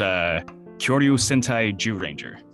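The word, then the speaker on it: Kyōryū Sentai Zyuranger: Let me know if I'm pronouncing this right. Kiaru Sentai Zairu Ranger. – Let me go full weeaboo for you. It's Kyoryu Sentai Jew Ranger.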